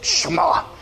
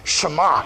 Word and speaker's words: Shema: No, say it with like a Jewish accent, like Shema. Get a little phlegm in it. Shema, yeah. Spit on somebody and you said it right.